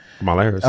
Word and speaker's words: Kamala Harris: Also, Kamala Harris. Mm-hmm. I say her name correctly. – Kamala Harris. – Okay, yes.